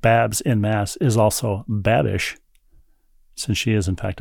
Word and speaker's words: Babish: Am I pronouncing his surname correctly? – B A B I S H? Yes.